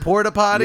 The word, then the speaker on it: porta potty: I hate having to use a porter potty for 5 days. – Whoa, the porter potty? The non-porter potty? – I'm saying it wrong. Porta? Is it porta potty? – Yeah, yeah.